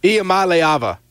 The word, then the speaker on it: Iamaleava: Iamaleava. That's how you pronounce it.